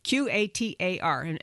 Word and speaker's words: Qatar: Russia and Qatar, depending how you want to say that. It's Qatar. And people, no matter how you say it, someone corrects you and says it the other way.